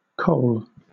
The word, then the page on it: kohl: British English